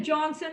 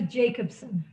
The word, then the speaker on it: Jacobson: Jackie, after all the stuff we've been through, you got my name wrong. You said Brenda Johnson instead of Brenda Jacobson.